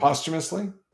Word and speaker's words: posthumously: How do you say that word? – Posthumously. – Thank you. For some reason, I can't get the posthumous. It's always post for me.